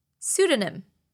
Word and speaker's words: pseudonym: Pseudonym. It's spelt P-S-E-U-D-O-N-Y-M. Bet you didn't imagine it to have the letter P at the beginning.